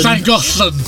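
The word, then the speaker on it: Llangollen: Llagollen.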